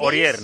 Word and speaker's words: Aurier: Aurier, ¿no? – Eso es.